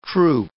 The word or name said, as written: troop